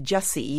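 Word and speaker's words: Gyasi: She's Ghanaian, and her last name is pronounced Jessie, even though it's spelled, I think, G-Y-A-S-I. A-S-I.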